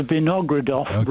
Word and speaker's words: Vinogradov: And she pronounced it as Vinogradov rather than as Vinogradov. I thought that, is this pronounced Vinogradov? She said, no, it's certainly in her case it was Vinogradov.